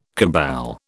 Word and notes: cabal: British English

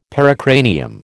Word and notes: pericranium: American English

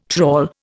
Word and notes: trawl: Indian

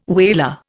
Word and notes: whaler: Indian

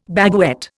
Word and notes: baguette: Indian

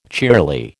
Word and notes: cheerly: American English

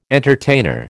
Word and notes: entertainer: American English

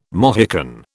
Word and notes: Mohican: British English

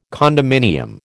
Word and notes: condominium: American English

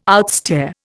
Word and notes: outstare: Indian

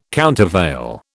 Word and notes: countervail: British English

